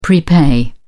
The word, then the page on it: prepay: British English